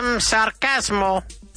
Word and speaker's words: sarcasm: Because sometimes the chasms and the asms and the isms have an extra vowel sound. So sarcasm. That's right. Okay? Sarcasm. So sarcasmo, the pronunciation sarcasm with the accent on the A there.